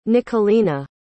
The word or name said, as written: Nicolina